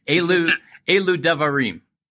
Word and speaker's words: Elu Devarim: and it's the Elu Devarim. Am I saying that correct or near correct? – That pronunciation was perfect.